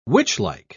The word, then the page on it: witchlike: British English